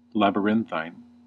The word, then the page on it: labyrinthine: American English